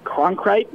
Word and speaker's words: Cronkrite: Jordan Cronkrite, I think I'm pronouncing that name right, but I'm probably wrong. – Yep. – I apologize. Yeah, the Cronkrite rhymes with concrete.